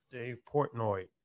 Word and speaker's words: Portnoy: Dave Portnoy. Is that how you say his name? – I believe so.